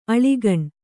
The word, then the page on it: ಅಳಿಗಣ್: kannada